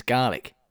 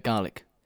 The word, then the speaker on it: Gaelic: Every time you say the word Gaelic, because I found out the way you actually pronounce Gaelic is garlic. Like no way, really? – As in garlic.